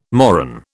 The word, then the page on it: moron: British English